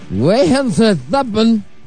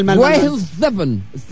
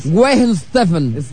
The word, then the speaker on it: Weihenstephan: Parece ser que la cervecería Weihenstephan. – No, mal. – No. – Mal. – Weihenstephan. – Stephan. – Bueno, perdona. Weihenstephan. – Weihenstephan.